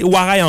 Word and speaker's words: warai: Donc quand tu tapes 5-5-5-5, c'est quelque chose de triste. Bon. Le caractère kanji pour rire est prononcé warai en japonais.